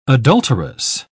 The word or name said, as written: adulterous